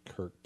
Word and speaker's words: Krk: It looks like it's about a 14-mile sail up to Kirk. K-R-K is how that's spelled. Yeah, I don't think I've actually been into Kirk, no.